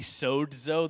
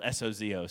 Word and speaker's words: sozo: In Greek, it's technically sozo. There's like a D in there, but you know, it's spelled S O Z O, so we're Americans. We don't pronounce that.